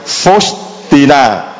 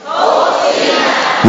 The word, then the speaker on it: Faustina: Để anh chị em tập phát âm cho nó đúng. Có nhiều người nói không biết đọc là thánh như thế nào. Thánh Faustina. Đó là thánh Faustina. Nhớ cao lên.